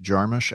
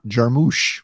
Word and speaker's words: Jarmusch: Fun thing about me is that this year, 2024, I have finally internalized that it's pronounced Jarmusch and not Jarmusch, which I've been saying most of my life.